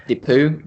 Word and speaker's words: Depew: Depew. – It's Depew, I believe. Depew, Dennis Depew. Yeah, it's French.